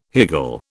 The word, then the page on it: higgle: British English